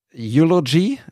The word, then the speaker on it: eulogy: Viele von diesen Fotos haben den Hashtag Eulogy, ist das richtig ausgesprochen? – Mhm. Mhm.